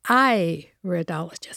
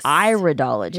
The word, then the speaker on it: iridologist: She is a colon hydrotherapist and internationally certified on the board, iridologist. – That's pronounced iridologist. – Iridologist.